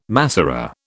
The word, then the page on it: Masorah: British English